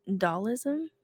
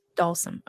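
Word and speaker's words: Dhalsim: Dalism, is that how you say Dalsum? – Dalsum.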